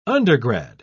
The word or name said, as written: undergrad